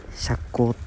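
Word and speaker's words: 赤口: 赤い口って書いて釈光って読むらしい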